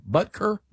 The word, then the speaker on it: Butker: Harrison Butker. How do you say his name? Butker? – Mm-hmm.